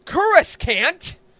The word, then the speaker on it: Coruscant: We're talking all about Coruscant. That's how you say it.